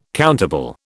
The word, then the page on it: countable: British English